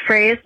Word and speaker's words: Fraze: It's Janessa Fraze. Larry, I forgive you for mispronouncing my last name. It's pronounced phrase like catchphrase.